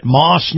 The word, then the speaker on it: Maus: And by the way, I got to tell you, this portion of the program is brought to you by our friends at Moss Nissan. Moss is spelled M-A-U-S, Nissan.